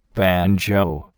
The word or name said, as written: banjo